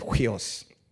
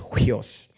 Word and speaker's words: huios: I know we all grew up in hearing it as huios, including me, until a few years ago when I started studying Greek and Hebrew more classically. It's actually pronounced huios.